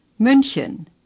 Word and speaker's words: München: Hamburg, Bremen, Berlin, Hannover, Dortmund, Essen, Düsseldorf, Köln, Frankfurt, Stuttgart, München, Wien, Zürich. – You notice that they sound quite different from the way you usually hear them in English, but many letters are pronounced the same as in English.